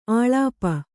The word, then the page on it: ಅಳುಪು: aḷupu